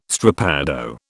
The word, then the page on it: strappado: British English